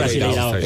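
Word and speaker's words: Brasileirão: ¿Se dice Brasileirao? ¿Cómo se dice? – Sí, Brasileirao.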